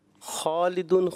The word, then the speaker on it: خالدون: اچھا بڑا, مگر خالدون میں خا جو ہے وہ قاف جیسا محسوس ہوا, خالدون خالدون, تو تھوڑا سا آپ کوشش کریں تلفظ کی ادائیگی پر زور دیں.